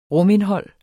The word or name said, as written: rumindhold